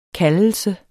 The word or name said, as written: kaldelse